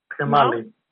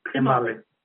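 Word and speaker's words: tkemali: So in the book, the Georgian, what's it called, Tasting Georgia, she talks about, how do you pronounce this, the sour plum sauce? – Kemali. – Kemali, yes.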